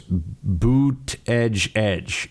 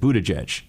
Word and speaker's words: Buttigieg: I've been told it's Buttigieg is the way to say it. Buttigieg.